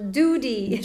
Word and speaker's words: Doody: Doudy, ça s'écrit comment ? – D-O-O-D-Y. – Ok, vraiment Doudy. – Doudy, ouais.